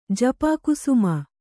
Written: ಜಪಾಕುಸುಮ

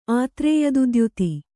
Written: ಆತ್ರೇಯದುದ್ಯುತಿ

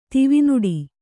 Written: ದಿವಾನಾಡಿ

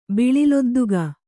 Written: ಬಿಳಿಲೊದ್ದುಗ

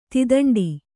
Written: ತಿದಂಡಿ

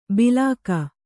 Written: ಬಿಲಾಕ

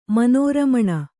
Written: ಮನೋರಮಣ

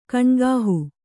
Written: ಕೈನೆಗಹು

